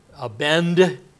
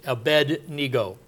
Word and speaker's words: Abednego: I don't know why we always end up pronouncing it Abednego. It's Abednego. Nego was one of the gods or something.